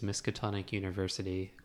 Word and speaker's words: Miskatonic University: And today we're talking about Miskatonic University. Did I say that right? – Yes. – Okay. – Okay, so I will describe it. The full name of this game is Miskatonic University, the Restricted Collection.